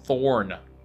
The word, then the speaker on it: Thorne: Thorne. – Oh, there's Thorne. – Okay. And there's a silent E.